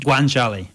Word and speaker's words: guanciale: Guanciale.